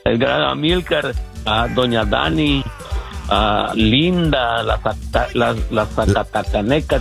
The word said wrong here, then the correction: Zacatecana: ¿cómo se dice? – Zacatecana. – La Zacatecana, sí.